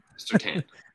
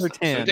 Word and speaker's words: Surtain: Surtain. I got that right the first Surtain.